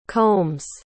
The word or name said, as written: combs